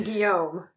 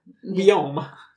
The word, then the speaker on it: Guillaume: I pronounce it in the French style. – Which would have been Guillaume. – Guillaume.